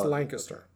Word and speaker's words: Lancaster: It's pronounced Lancaster.